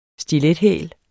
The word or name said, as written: stilethæl